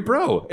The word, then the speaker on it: Breaux: Oh, it's Harry Bro. B-R-E-A-U-X. French, everybody.